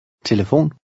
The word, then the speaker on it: Telefon: Numbers 6 and 7 are words of foreign origin, and the accent is on the last syllable. Read number 6. – Hotel. – 7. – Telefon.